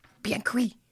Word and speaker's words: bien cuit: The first word is B-I-E-N and the second word is C-U-I-T. And C-U-I-T. – Yeah. So it's like. – Bien cuit. – I know. It's bien cuit. But you have to say it in French if you want to say it right, which makes me uncomfortable.